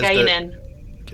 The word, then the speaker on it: Guinan: Um, the one person I just and, and we're going to see her next season is is Guyan, like Whoopi Goldberg. Yeah. – Um, but I think after Guyan, um, you know, Deanna's. – Guinan. – The, sorry, Guinan. Did I say it wrong?